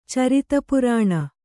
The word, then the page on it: ಚರಿತಪುರಾಣ: kannada